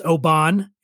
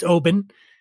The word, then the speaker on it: Oban: which people often mispronounce Oban. It's Oban.